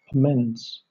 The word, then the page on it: amends: British English